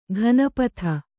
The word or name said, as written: ಗಣಪದ